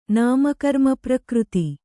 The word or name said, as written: ನಾಮಕರ್ಮಪ್ರಕೃತಿ